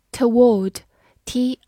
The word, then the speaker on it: toward: toward，t o w a r d，toward，t o 发 t a t，w a r d，ward，toward，美式发音会更加随意一些，直接可以读 toward，toward。中间的音就直接带过去了。